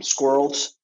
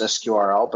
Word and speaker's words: SQRL: So the name first, we thought about squirrels, and we thought as a startup, we can't afford any vowels. So, we've gone around, and we've definitely heard it pronounced as SQRL, but also squirrel is the intent.